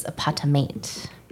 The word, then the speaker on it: Patamate: the other one that I feel like we've talked about a lot, and we've always referred to him as Paramate, but I think we've come to the realization that we are saying his name incorrectly, and I think it's Patamate.